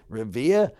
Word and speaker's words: Revere: Revere would be pushing it, but, you know, Revere? – He's alluding to a town in Massachusetts, and that's how they pronounce it.